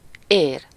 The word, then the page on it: veine: /vɛn/; France